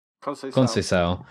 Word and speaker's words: Conceição: Conceição.